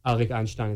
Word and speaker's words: Arik Einstein: And like my namesake is also from a song by an artist named Arik Einstein, is how you say it, called Avshalom, which is my name.